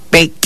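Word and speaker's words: peek': En lengua maya lo pronunciamos de esta manera. Pec. Pec. Pec. Así como suena. Pec. Sin forzarnos.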